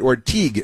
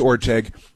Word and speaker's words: Orteig: So that's and again, they mispronounced it Orteig. It's Orteig, but that's okay.